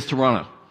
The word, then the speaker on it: Toronto: Although he's from there, so he actually says it correctly and says Toronto, because they don't actually pronounce that second T, so I don't know why it's in the word if they're not going to actually say it.